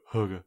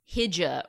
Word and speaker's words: hygge: You say You see it on a book. It's like H-Y-G-G-E. And then I actually listen to the little book of Hugga on an audio book or about half of it. And every time they go Hugga. – Every time? – It's like Hugga. – Like I've been saying Hija or Hyga. Like I've done every mispronunciation possible because the pronunciation just doesn't make any sense to an English speaker.